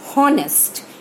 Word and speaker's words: honest: We do not say honest. H, in spite of being a consonant, H is silent in the word honest.